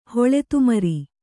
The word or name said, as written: ಹೊಳೆತುಮ್ರಿ